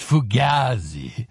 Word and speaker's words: Fugazi: It's Fugazi. Pronounced Fugazi. Fugazi.